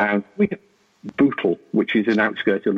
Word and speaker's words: Bootle: B O O T L E. – B oh, like Google only with the B.